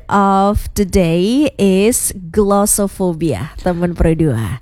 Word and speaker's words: glossophobia: So it's spelled GLOSSOPHOBIA, mm-hmm, glossophobia.